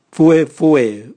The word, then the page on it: vuevue: Raga